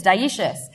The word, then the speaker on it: dioecious: It is. It's dioecious. But it's spelled really interestingly, dioecious, as opposed to monoecious. It comes from the Latin die for two and the Greek oikos, which means house.